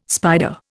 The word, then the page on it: spider: Indian